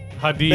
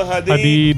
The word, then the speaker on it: Hadid: Hadid. – Bella Hadid.